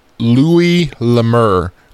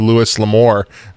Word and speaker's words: Louis L'Amour: So I had to go to a website, and it is Louis Lemur. According to Book Browse, but I always called him Louis L'Amour because, you know, I just read it and he's French and, you know, I hate names.